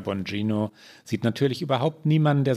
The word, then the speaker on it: Bongino: In dem Ausschnitt fragt, äh, wie spricht man ihn aus? Bongino? – Ja, so habe ich es gehört. So stellt er sich selbst vor.